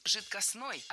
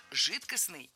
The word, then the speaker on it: жидкостный: Итак, прозвучали варианты «жидкостный» и «жидкостной». А какой из них верный? Вариант «жидкостный» является литературной нормой. Ударение исходного существительного «жидкость» в нем сохраняется, но окончание должно быть «ый». «Жидкость» – «жидкостный».